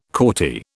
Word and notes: Corti: British English

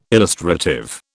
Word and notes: illustrative: British English

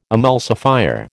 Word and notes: emulsifier: American English

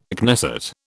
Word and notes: Knesset: British English

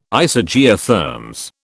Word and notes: Isogeotherms: British English

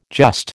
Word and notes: just: American English

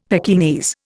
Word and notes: Pekingese: Indian

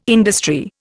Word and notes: industry: Indian

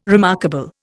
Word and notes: remarkable: Indian

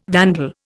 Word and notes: vandal: Indian

